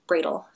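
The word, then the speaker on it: Brödel: It's spelled B-R-O-D-E-L, the last name, so it's a little hard to find, but it's Bradle. It's German. But he says, you know, you can't really truly draw something if you don't understand it.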